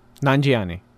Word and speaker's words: Nanjiani: Am I saying it right? – Close enough. – Just say it. – Nanjiani. – Kumail Nanjiani. – Perfect.